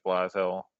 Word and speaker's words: Blashill: I think right now, before the end of the season, Guy Boucher, Jeff Blashill. Forgetting if that's how you say his name now. Sorry, Charlie. – Yeah, close enough.